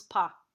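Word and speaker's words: Pa (по): And even like full words like this P-O, and it's not a strong syllable, this becomes just a dark A, right? This just becomes Pa.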